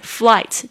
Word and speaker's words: flight: I'm a flight attendant， flight attendant 就是空服员， flight 就是飞机的， attendant 就是服务员， flight attendant flight 我把它拼一下， F-L-I-G-H-T， G-H 不发音， attendant A-T-T-E-N-D-A-N-T， flight attendant，注意一下，好像有时候可以把它讲得很快，可以把它连音哦，所以你一定要熟悉那个连起来的声音， flight attendant。